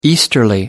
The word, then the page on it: easterly: American English